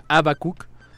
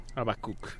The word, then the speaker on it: Abbacook: Abacook. Eh, se escribe A B B A C O O K Abacook.